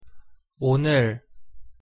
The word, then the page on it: 오늘: Korean